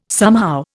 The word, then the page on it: somehow: Indian